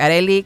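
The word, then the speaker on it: Arelly: Quien quiera aprovechar hoy la noche de San Juan, acuérdense, por ahí les dejé varios tips en mi Facebook, Arely con doble L y griega, así se escribe mi nombre, Arely Alcocer, locutora y terapeuta.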